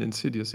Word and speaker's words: Insidious: Und genau, wir sind dann am gleichen Abend sogar nach Saarbrücken ins Kino und ja, ich habe den Titel des Namens sogar falsch ausgesprochen, weil ich dieses Wort gar nicht kannte. Ich habe dann Inside Insidious gesagt. – und der, der, Typ hat dann gesagt, ah, Sie meinen Insidious.